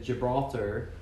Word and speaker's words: Gibraltar: So basically the way that this came into the public eye was because this ship, the De Gracia and Captain Morehouse, took it to Gibraltar. Is that how you pronounce that? – That sounded really right.